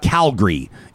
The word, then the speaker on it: Calgary: Well, you know that if you say Calgary, you are not from Calgary. – Here. Yeah. – It's Calgary. It's the Calgary Flames, the Calgary Tower, and the Calgary variant at the Calgary Stampede. That's how you pronounce it.